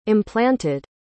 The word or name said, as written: implanted